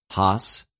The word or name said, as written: ὁς